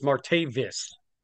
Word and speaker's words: Martavis: Now, I will disagree with you a little bit in the fact that I think they're taking a flyer on Martavius Bryant. Is I get that get did I get his name right? Because no BS. It's Martavis.